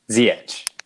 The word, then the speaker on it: Ziyech: Um, the other one I'm interested in is, um, I don't know how to say his name, Zayek? Am I saying that Ziyech. right? – Ziyech.